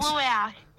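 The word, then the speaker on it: luau: And you've got the, is it Luai? Is that how we pronounce it? Is this your personal event? – Luai, yeah.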